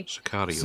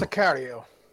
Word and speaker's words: Sicario: Sicario. – Sicario.